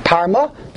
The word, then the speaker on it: Parma: Sefer HaChasidim Al Pi Kisveyad Parma. Am I pronouncing that correctly? – Yeah. – Parma.